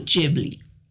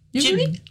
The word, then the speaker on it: Ghibli: So, Studio Ghibli. – Studio Ghibli is the topic. – That's the American pronunciation of it. Ghibli? – Ghibli? – Ghibli. Ghibli. In Japan, I think they pronounce it a little bit.